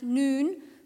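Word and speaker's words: Noon (ن): the Arabic letter N, pronounced Noon, for Nazrani or Nazrin, the follower of Jesus the Nazarene.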